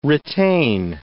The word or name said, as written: retain